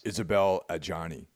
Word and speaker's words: Isabelle Adjani: Isabel Adjani.